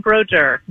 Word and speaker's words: Broduer: It's close enough. It's Adrienne Broduer.